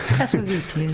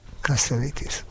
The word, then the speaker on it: Cassavetes: Az te tudod, hogy kell Cassavetes nevét kiejteni? – Cassavetes. – Cassavetes? Igen, én mindig Cassavetesnek mondtam. – Én is Cassavetesnek mondtam. – Igen, egy amerikai barátnőmtől hallottam. – Ja, kiejtette, és így kell.